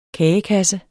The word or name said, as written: kagekasse